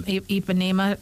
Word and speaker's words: Ipanema: Ipanema.